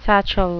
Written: satchel

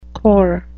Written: corps

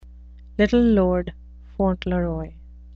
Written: little lord fauntleroy

